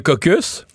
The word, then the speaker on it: caucus: Euh, Jean Lapierre disait parfois que au parlement, il y a les caucus. Ouais. Mais dans certains cas, on prononce pas le S.